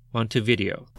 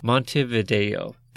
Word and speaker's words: Montevideo: In 2018, I moved back to my hometown, Montevideo. – Montevideo.